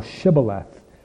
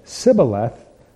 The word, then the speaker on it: Shibboleth: If he said, "Nay," then they said unto him, "Say now Shibboleth." And he said, "Sibboleth," for he could not frame to pronounce it right.